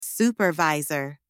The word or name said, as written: supervisor